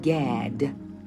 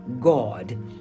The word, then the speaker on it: Gad: Oh, now, but people using the English pronunciation say Gad, but the correct pronunciation is God. You know where they say, believe in God, you would spell it made G-A-W-D, God.